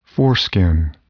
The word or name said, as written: foreskin